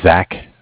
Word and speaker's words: Zach: My name is Zach, spelled Z-A-C-H. Mm-hmm. I am calling from Bank of Cardiff. Cardiff is spelled C-A-R-D-I-F-F.